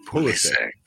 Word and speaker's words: Pulisic: He says it himself, and in the U.S. it said as Christian Pulisic. – Pulisic. – Now, if you were to pronounce it in the Balkan languages, it'd be said as Pulisic.